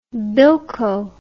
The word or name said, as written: bilko